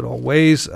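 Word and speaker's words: Alvvays: Spelled with two V's instead of a W, but I guess you pronounce it Always.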